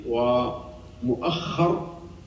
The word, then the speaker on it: مؤخر: لكن الذي جاءت به الروايات والذي سمع من أقوال الشيوخ أنه بمقدم رأسه، بمقدم رأسه ومؤخر أيضا هكذا تنطق بالتشديد، تشديد الخاء.